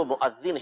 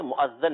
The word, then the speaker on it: مؤذن: یعنی صحیح تلفظ جو ہے وہ مؤذن ہے، مؤذن نہیں ہے.